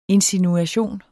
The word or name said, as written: insinuation